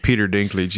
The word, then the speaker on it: Peter Dinklage: Peter Dinklage.